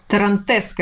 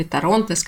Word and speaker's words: Торронтес: Мы сегодня с ней вместе поговорим о двух винах из Аргентины, о сортах Мальбек и Тарантес, как правильно говорить, или Торонтес, как, может быть, вы привыкли слышать.